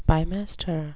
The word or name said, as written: bimester